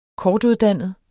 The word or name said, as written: kortuddannet